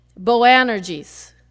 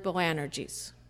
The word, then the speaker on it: Boanerges: Maybe I'm saying it right, maybe not. Maybe Rick can tell me. Boanerges. Close enough? Yeah.